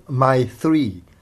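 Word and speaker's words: My Three (मैत्री): 友情在梵文里叫 Maitri，读成 My Three，我的三个。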